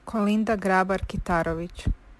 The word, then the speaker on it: Kolinda Grabar-Kitarović: but you butchered her name. I attached an audio clip of me saying her name correctly, so here it is. The Croatian president, who is not Coco. – Kolinda Grabar-Kitarović. – My, we were so wrong.